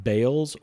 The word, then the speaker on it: Baal: Baal is probably the right way to pronounce that. It's B-A-A-L, Baal or Baal. I'm going to say Baal because it's easier, and I'm probably going to be saying it a few times.